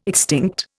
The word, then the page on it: extinct: Indian